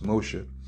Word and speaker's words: Moshe: However, was it possible for Moshe, which is in Hebrew, his name is pronounced Moshe, or Moses' hands is influenced this battle?